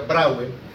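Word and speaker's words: Brouwer: Se escribe Brauer, se pronuncia Brauer.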